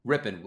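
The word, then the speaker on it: Rypien: Rippon, so like Mark Rippon, yeah,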